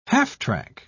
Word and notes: half-track: British English

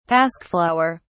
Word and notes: pasque flower: British English

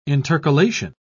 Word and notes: intercalation: British English

